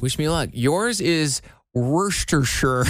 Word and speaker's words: Worcestershire: Worcestershire. – Worcestershire. – So it is, they have it in three different syllables here. Like wuh, then stu, and then shure. – Worcestershire.